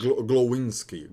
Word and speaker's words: Glowinski: O, é, como é que fala o nome do nosso guarde? É Glowinski? Goncols? Glowinski. Glowinski. Cara, esse cara jogou absurdos também do lado do, do Gates, que aí eu tenho que bater palma também.